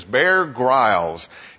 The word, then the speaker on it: Grylls: Wild is a show with a fellow named Bear Grylls. Some pronounce it Grylls, but I understand the correct pronunciation is Bear Grylls.